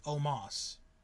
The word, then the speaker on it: Omos: Yeah, it's spelled Omos. – Yeah. – But they pronounce it Omos.